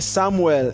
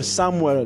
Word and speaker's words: Samuel: Sam, S-A-M, then Samuel, full Samuel, that's S-A-M-U-E-L, then Famolu is F-A-M-O-L-U.